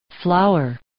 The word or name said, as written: flower